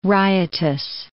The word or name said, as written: riotous